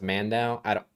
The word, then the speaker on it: Mando: I think it's pronounced Mando. Yes.